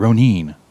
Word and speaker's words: Ronin: We all pronounce it wrong when we say Ronin. It's Ronin, whatever.